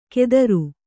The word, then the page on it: ಕೆದರು: kedaru